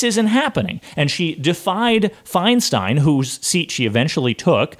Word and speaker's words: Feinstein: I never know how to pronounce it. it's Feinstein. It's Feinstein thank you, thank you, Feinstein. I, I always pronounce it wrong.